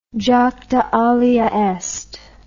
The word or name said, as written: jacta alea est